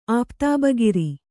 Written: ಆಪ್ತಾಬಗಿರಿ

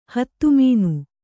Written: ಹತಮನ

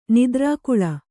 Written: ನಿದ್ರಾಕುಲ

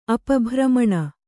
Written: ಅಪಭ್ರಮಣ

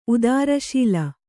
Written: ಉದರಶೂಲೆ

